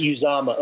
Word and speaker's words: Uzomah: Is Uzomah okay? I believe that's how his parents pronounce his name.